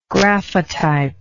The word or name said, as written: graphotype